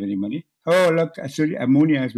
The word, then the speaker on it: Moonias: Rightfully, it's usually spelled M-O-O-N-I-A-S. – Yes. – Yeah, Monias. As in, it rhymes with Sunia, which means a little bit of money.